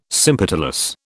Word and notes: sympetalous: British English

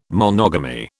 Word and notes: monogamy: British English

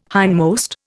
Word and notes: hindmost: Indian

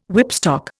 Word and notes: whipstock: Indian

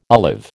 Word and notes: olive: American English

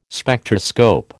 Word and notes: Spectroscope: American English

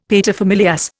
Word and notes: paterfamilias: Indian

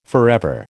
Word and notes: forever: American English

